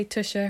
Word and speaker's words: Tuxa: She was always, also, if you're wondering, Tusha, when I say Tusha, her name is spelled T-U-X-A. It's a very Portuguese dog name, but it's beautiful.